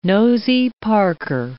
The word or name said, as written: nosey parker